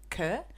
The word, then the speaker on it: Kaur: How do you say K A U R? Kerr. You know how you read a name on Instagram or whatever or in your phone, Kua, but you never K- say it out loud.